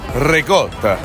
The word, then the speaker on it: ricotta: It's very common for people to say ricotta, because that's how it's spelled, but it's really pronounced. Are you ready? Ricotta.